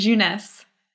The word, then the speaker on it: Jeunesse: Jeunesse.